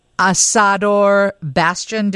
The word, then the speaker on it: Asador Bastian: And then Asador Bastion. Do I say that right? – You did with flair.